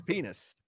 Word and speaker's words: Penix: I even looked at Penix, and I learned how to say his last name right because it's borderline penis. Bad. Yeah. – It's penis. It's real. Yeah, tough.